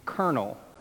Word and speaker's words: colonel: A more notable word might be colonel. No, seriously, it should be spelled K E R N E L, or it should be pronounced a completely different way.